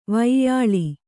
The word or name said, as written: ವ್ಯಾಲ